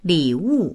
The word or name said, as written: li3wu4